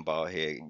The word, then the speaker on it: ball-headed: I would agree that it's written ball-headed, but it's pronounced ball-head. It's unapologetic, unabashed, in your face.